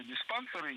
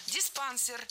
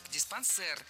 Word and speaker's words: диспансер: У нас такая традиция в усадьбах было устраивать туберкулезные диспансеры и психические, неврологические всякие. – Диспансер. Неверно. Это тоже французское слово, и ударение в нем тоже падает на последний слог. Диспансер. Кстати, произносим с «э». Диспансер.